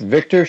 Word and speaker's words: Victor: It's Victor Schueller.